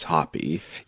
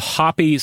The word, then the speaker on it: Hapy: He is a god whose name in English is usually spelled H-A-P-Y. And I have not, I searched in vain to find a correct pronunciation for this name and could not, but I think it's Hapi.